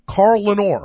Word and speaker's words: Corlanor: It's called Corlanor. – It's called, look, so it's spelled C-O-R-L-A-N-O-R. – It's like A-N-O-R. Carl Lenore.